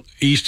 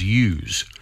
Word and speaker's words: yous: And I think we think of the great boxer Jeff Fenwick, who used to say, I love yous all. – Yes. – But so the word is use. – Spell it, can you? – Well, you could spell it Y-O-U-S or sometimes Y-O-U-S-E.